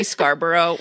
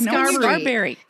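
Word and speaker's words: Scarbury: Does anyone else ever say Joey Scarborough when they say his name? – That's what I thought it was. – No, it's Scarberry. It's one of those things that I'm like, what? It's not Scarborough.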